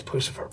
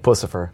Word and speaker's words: Puscifer: It's Puscifer, because that's the right pronunciation always, right? – Puscifer. – Puscifer. Oh, yeah. It's Puscifer. Okay, I love it.